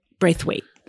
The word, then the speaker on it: Braithwaite: It's, and I'll try to pronounce her name correctly. It's Oyinkin Braithwaite. – Braithwaite, that's right.